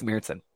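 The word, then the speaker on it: Märzen: What did Rob tell us? Rob told us the right way to say it. Marzen, right? – Right. I think it's Marzen.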